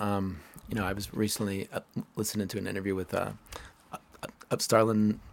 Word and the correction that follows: Sterling Harjo: Sterling Harjo, yeah.